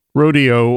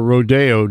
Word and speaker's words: rodeo: The Washington State Fair is already underway, but that is the beginning of the rodeo or rodeo, depending on one's proclivities and pronunciation.